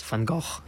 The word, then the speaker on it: Van Gogh: The standard pronunciation is Van Gogh.